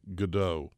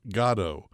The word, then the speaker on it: Godot: Americans have always pronounced the name Godot, while the playwright has pronounced Godot.